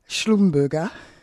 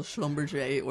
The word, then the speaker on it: Schlumberger: it's been revealed that multinational companies Schlumberger. I always thought Schlumberger. – Well, it's also, it's pronounced in a kind of French way as well, Schlumberger or Schlumberger, but most people I've heard are saying Schlumberger.